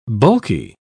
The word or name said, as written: bulky